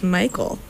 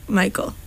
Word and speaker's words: Mikal: My middle name is Michael. So, really? – In case you were wondering, yes. – Wait. – But it's spelled like Mikal, but it's pronounced like Michael. – What? – How do you spell like Mikal? – M-I-K-A-L.